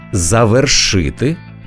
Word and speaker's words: завершити: Правильно казати – завершити, завершив, завершимо.